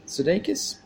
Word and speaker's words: Sudeikis: Sudeikis.